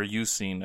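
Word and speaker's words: Eusine: Or Yusin, as I've since learned it's probably pronounced. Which actually makes sense. I don't know why I ever said Isuin, because EU... is the beginning of Europe, which is you.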